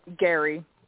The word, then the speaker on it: Guary: Yeah, it's Gary. – Brittney Guary. Okay. – Well, G U A R Y. Yeah, the U is silent.